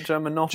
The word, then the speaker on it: Germanotta: Isn't it Germanotta? – Germanotta.